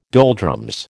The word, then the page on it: doldrums: American English